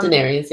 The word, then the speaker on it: Daenerys: Well, I think he was into Daenerys, I think that's how you say it, but Daenerys, yeah.